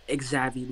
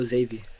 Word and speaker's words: Xavi: I've heard Lil Xavi, I've heard Lil Javi, I've heard Lil Xavi. No, it's Lil Xavi. Lil Yeah. – Xavi. Like wavy, but Xavi.